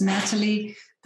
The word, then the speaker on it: Nathalie: Okay, so it's Natalie, that's spelled N-A-T-H-A-L-I-E. And then my last name, Himmelrich, it's like H-I-M-M-E-L, which means heaven, actually.